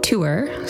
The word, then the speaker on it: TOOER: So the retreat is called Through Our Own Eyes, T-O-O-E-R, and I noticed people have been pronouncing it tour, so I'll pronounce it that way.